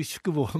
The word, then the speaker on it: shukubo: Oui, au Japon, on peut loger dans des shukubo, si je prononce bien. – Oui, shukubo, mais okay.